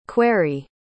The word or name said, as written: quarrie